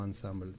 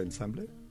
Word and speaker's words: Ensemble: No es fácil, porque hay una palabra en alemán, Kultur, con esa K que está tan pronunciada, y luego Ensemble, que hay una E muda, que por mucho que uno estudie francés, siendo español, es difícil.